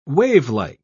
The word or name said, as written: wavelike